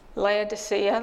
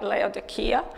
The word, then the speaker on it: Laodicea: and he named the city Laodicea. That's probably not the correct way to say it. It's how we all say it. I think it's more like Laodicea, after his wife Laodice.